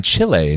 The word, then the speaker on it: Chile: She's with Team Canada in the three-on-three version of Team Canada down in Chile. And you said it correctly there.